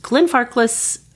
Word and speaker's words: Glenfarclas: It is, in fact, the Glenfarclas. – Yes, you said it correctly. We had to look it up just to be sure. – The Glenfarclas single malt scotch.